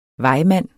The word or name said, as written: vejmand